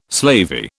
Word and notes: slavey: British English

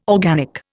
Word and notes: organic: Indian

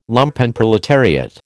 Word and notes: Lumpenproletariat: American English

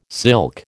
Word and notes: silk: American English

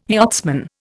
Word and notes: yachtsman: Indian

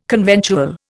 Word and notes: conventual: Indian

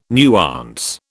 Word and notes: nuance: British English

